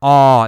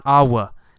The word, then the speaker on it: awe: All right, we see the word awe here, A-W-E, awe. It's pronounced awe, awe, awe. You don't actually say a W sound. There's not awa. No, it's awe.